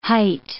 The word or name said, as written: hight